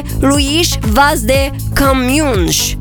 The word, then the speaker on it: Luís Vaz de Camões: care spune Luís Vaz de Camões, dacă nu greșesc eu așa se pronunță.